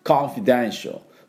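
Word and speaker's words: confidential: ギリス人だと、コンフィデンシャル、コンフィデンシャルみたいな音になると思います。なるか、なるか。ね。大きく開けるのか小さく開けるのか、この違いです。あと F はフッフッフッフッね、しっかりで、このコンフィのところは半母音ですから、コンフィと強く出さなくていいです。コンフィデンシャルで、デンシャルのところにアクセントがあります。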